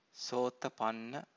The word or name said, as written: sotapanna